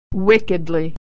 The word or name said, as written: wickedly